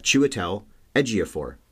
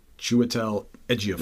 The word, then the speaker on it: Chiwetel Ejiofor: I said 2 weeks ago, it's phonetic, except for the I. So think of C-H-E, and you've got Chiwetel Ejiofor. – Chiwetel Ejiofor. – Right there. Look there. See? So it's C-H-I-W-E-T-E-L.